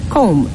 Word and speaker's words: comb: So, the first one, whenever you find MB at the end of a word, just know that the B will not be pronounced. So we have a word like comb. Now you know why you didn't say comb.